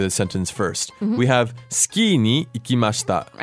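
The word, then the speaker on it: ski (スキー): So it's ski with a long I at the end.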